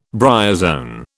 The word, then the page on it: bryozoan: British English